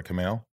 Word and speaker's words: Kumail: Andrew C., how jealous are you of Kumail's, I said that right, Kumail? – Yeah, Kumail.